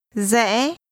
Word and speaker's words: Rẽ: Rẽ. Rẽ. Rẽ. – This is pronounced with a high rising, broken tone.